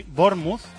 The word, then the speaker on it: Bournemouth: Lo está ganando 0-2 al Bournemouth, que me ha dicho un oyente que se dice así, Bournemouth.